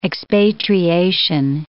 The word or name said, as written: expatriation